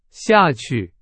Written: 下去

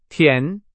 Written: 甜

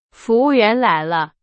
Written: 员

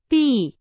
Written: 避

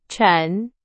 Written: 沉

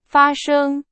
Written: 发生

